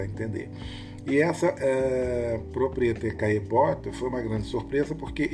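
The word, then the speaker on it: Caillebotte: A gente não escreve, a gente não fala Caelebote, a gente tem que suprimir esses dois L's, então fica Caebot e é assim que a banda toca no francês.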